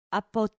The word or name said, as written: apo